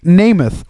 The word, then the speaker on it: Namath: It's pronounced Nick Namath, which sounds even more badass.